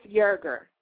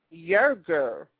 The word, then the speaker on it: Yerger: It's pronounced Yerger. Yerger. – Mm-hmm. Y E R G E R. Yerger.